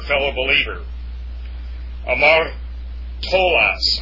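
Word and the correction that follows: Hamartolos (ἁμαρτωλός): actually it's a rough breathing, pardon me. It's Hamartolos, that's a sinner.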